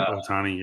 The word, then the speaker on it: Ohtani: Yep, Ohtani.